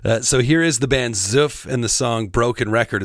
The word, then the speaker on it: Syff: But I laughed a little bit when I said the name there a moment ago, and that's because I had to ask the band how to pronounce it. Because like I said, it's spelled S-Y-F-F. And I couldn't help but laugh because this is, this is the audio file that was sent to me.